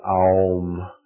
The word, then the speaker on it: Om: Om. It's actually pronounced Aum.